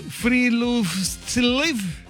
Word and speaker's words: Friluftsliv: En algunas regiones de Noruega existe una tradición conocida como Tirlufusliv. ¿Cómo se pronuncia? Es Friluftsliv.